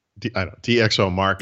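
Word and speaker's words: DxOMark: Now, with the Deox, how do you say it? – DxO Mark is how I would say it.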